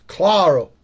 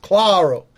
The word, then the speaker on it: Claro: And by the way, people, it's Claro. Paul Claro. so fucking my excuse for my fucking error. – Yeah, that helps. If you pronounce it with the accent, it helps. Claro.